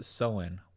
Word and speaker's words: Samhain: Oh, pronounced Samhain. Wow, that English language is wild.